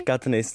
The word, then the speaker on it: Katniss: Není, Katniss je jako mě. Že to vyslovuješ Katniss, což je v tom dubbingu původním českým a takhle to tam říkali a mě jsem se tomu vždycky hrozně smál.